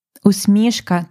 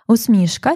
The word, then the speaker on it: усмішка: Усмішка твоя єдина. Усмішка часто ми наголошуємо як усмішка, але тут у поезії і загалом це теж правильний наголос казати усмішка. Можна казати і так, і так.